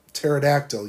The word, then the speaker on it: pterodactyl: Why can't you hear the pterodactyl use the washroom? Why can't you hear the pterodactyl use the washroom? – Because the P is silent.